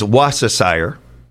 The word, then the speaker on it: Worcestershire: The most accepted pronunciation in American English is Worcestershire. The key is to drop the R and soften the C. So Worcestershire.